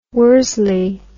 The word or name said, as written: worsley